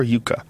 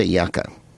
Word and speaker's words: yucca: You say yucca or yuca? How do you say it? – I would say yucca.